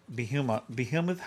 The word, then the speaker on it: behemoth: Yeah, this is where the phrase in the original Hebrew implied that behemoth, how do you say it? – Behemoth was the biggest animal ever created.